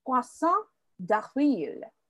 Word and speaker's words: poisson d'avril: No, I'm probably not saying it correctly. It's April fish. – Oh, poisson. Oh, sorry, I think it's poisson. Oh, okay. – Poisson d'avril. What did you think I said? – I, for some reason, I thought you said garçon. – Oh, no. Well, it's similar. – But you did, you pronounced it correctly.